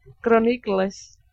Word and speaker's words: Chronicles: No, es que lo que es decir Chronicles. – ¿Cómo se dice? – Chronicles. – Muy bien.